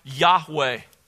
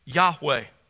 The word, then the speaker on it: Yahweh: It's probably pronounced, we don't have the vowel pointings preserved in the manuscripts that have been passed down to us, only the consonants, but it was probably pronounced something like Yahweh, Yahweh.